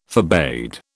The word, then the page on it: forbade: British English